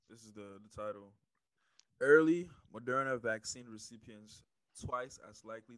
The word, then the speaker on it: Moderna: But you just said something that piqued my interest about, you said, was it Moderna? I don't know how to say it. – Yes. It was Moderna.